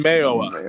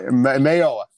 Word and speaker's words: Mayowa: I think between Benson Moya. Did I say that right again? I always say his name. – Benson Mayowa. – Mayowa. Not the other way.